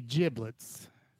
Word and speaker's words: giblets: Basically, giblets, pronounced with a soft G like gin, rhymes with riblets, are what we collectively call any of the edible organs of fowl, like turkey, as well as chicken, goose, and duck.